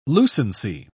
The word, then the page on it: lucency: British English